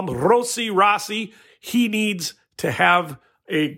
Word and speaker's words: Rossi: So he was called Rossi. Then he told press late in the year, actually, it's pronounced Rossi. Roll that R, Rossi.